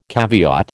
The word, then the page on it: caveat: American English